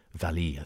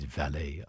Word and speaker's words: Vallee: Vallee, I think sometimes you hear him pronounced, but it is Vallee, I believe.